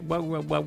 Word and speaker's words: Huawei: Huawei. – La H es muda.